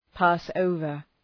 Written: pass over

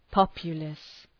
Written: populace

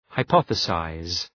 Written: hypothesize

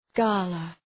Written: gala

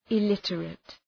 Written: illiterate

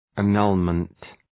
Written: annulment

